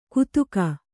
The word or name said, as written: ಕುತುಕ